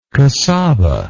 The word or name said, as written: cassaba